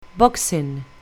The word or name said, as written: boxing